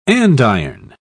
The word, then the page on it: andiron: British English